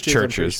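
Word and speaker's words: CHVRCHES: I know I just kind of rambled about wrestling for a while, was Churches or Chiverches. – How are you supposed to say it? I've never heard it before. – It's Churches. Sure. Yeah. – Okay. – But it's spelled Chiverches.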